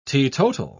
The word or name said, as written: teetotal